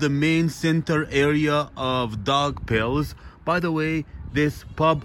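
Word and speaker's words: Daugavpils: and My Thoughts on Dog Pills. So, the city I'm in, guys, to pronounce it properly, you pronounce it dog pills. Yes, if you say dog pills, they'll know what you're talking about.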